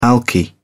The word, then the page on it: alky: British English